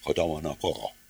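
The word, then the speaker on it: Kodomono Koro (子供の頃): Nii oli see sõna, kõigepealt alustame Kodomono Koro. See tähendab, hästi, need jaapanikest tuleb R-täht veel tagasihoidlikumalt hääldada kui inglise keeles. Tähendab Kodomono Koro, kohe nii.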